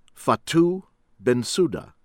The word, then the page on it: Fatou Bensouda: American English; from Gambia